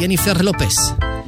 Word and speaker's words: Jennifer Lopez: Hát már koromnál fogva nem tudom, hogy Gillónak vagy Jennifer Lopeznek kell mondani, de hát, valami új sláger tőle. – Figyelj, a legújabb Jennifer Lopez, a legalább megvan a spanyol akcentus, tudod, Jennifer Lopez.